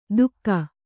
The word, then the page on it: ದುಕ್ಕ: dukka